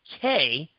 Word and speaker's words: que: Q-U-E. And here's the thing. There is no such damn word as Q-U-E, okay? Q-U-E is actually a Spanish word pronounced K, and that is not what I think Sonny Siler was going for.